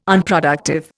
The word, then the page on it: Unproductive: Indian